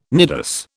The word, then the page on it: nidus: British English